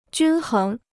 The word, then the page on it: 均衡: jūn héng